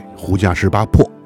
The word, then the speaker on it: 胡笳十八拍: 这个，我们给大家准备了一道大菜，大菜胡家十八拍，胡家十八破啊。这个所谓这个拍的这个音，在读音当中，呃，据说是要被读成破的这个音。哎，胡家十八破。